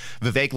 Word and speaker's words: Vivek: And by the way, I think we've been pronouncing it wrong. I think it's Vivek. Vivek like cake is how I remember that.